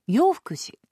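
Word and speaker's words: 永福寺: 永遠の永に幸福の福そして寺と書いて洋福寺と読む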